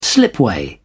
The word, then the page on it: slipway: British English